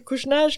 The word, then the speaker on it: Kouchner: Kouchner ska uttalas som Robert.